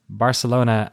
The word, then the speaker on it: Barcelona: please consider the unambiguous correct pronunciation of these two geographical locations, Barcelona and Azerbaijan. What do I say? What do I usually say? Do I say Barcelona? Is that what he doesn't like? I say it like a Spaniard.